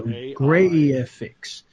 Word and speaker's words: Graiphics: So the software that I've been using over the last few days is called Graphics, but it's spelled GRAIPHICS. So Graphics.